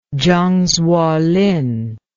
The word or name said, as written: zhang zuolin